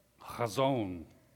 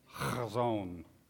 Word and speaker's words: Chazon: Chazon. That's a fun word to say. It's like the chet, you pronounce an H sound, at the same time you're clearing the back of your throat. Chazon.